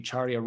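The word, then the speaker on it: Carya: It's called Charya Rising, and it's spelled a little bit differently. It's it comes from a Sanskrit word. And so it's actually CARYARISING, Charya Rising.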